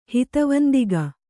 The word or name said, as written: ಹಿತವಂದಿಗ